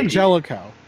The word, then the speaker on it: D'Angelico: I always want to say D'Angelico. – Okay. Oh, I was... For some reason, my brain was getting rid of that G, and I was like, wait, I know there's a G in here somewhere.